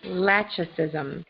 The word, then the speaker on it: lachesism: Lachesism. – Use in a sentence, please. – I'll spell it first. L-A-C-H-E-S-I-S-M.